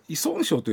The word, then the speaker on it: 依存症: これはあの依存症と読むと言われて、はい、人生で初めてあこれ依存症ちゃうんですかみたいなこと言うた覚えはある。